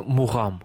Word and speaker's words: мугам: Кстати, мугам, как на азербайджанском мугам звучит? – Произносится мугам. – Мугам, да. Да. В грузинском тоже так, это гортанный звук, но вот в русском варианте это мугам.